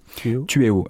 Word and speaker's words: Tueo: Tueo. Alors, ça s'écrit T-U-E-O. – D'accord. – Tueo, exactement. Tueo. Ça se d'accord.